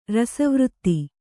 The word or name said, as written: ರಸವೃತ್ತಿ